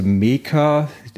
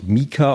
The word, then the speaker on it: Meeker: Also wenn man mal mit den Sommerhimbeeren anfängt, die Sorte Meka, die schreibt sich M-E-E-K-E-R, wird, glaube ich, Mika ausgesprochen.